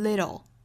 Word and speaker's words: little: 音来读一下我们注意中间的断句中间断句呢我分别会在 sometimes 的后面 beginning 的后面 pain 的后面 sometimes a little discomfort in the beginning can save a whole lot of paind own the road 好我们再来看美音美音里 little 这个词会发成 little 注意里面的这个字母 t 对应的是一个弹舌的类似于的发音但是又不是十足的位置我们可以说是舌尖在硬腭的位置一弹而过